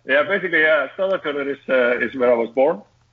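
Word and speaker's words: Stöðvarfjörður: It's Stodvarfjordur. Is that about right? – Not bad. Yeah, not bad. That's pretty close to it. Stodvarfjordur. Yeah. Yeah, you're right.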